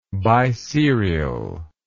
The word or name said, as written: biserially